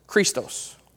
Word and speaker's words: Christos: And so you pronounce Christ like this, Christos.